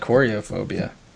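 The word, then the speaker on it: Koryophobia: K-O-R-Y-O phobia. Coryophobia. – Yeah, I would say like coryophobia.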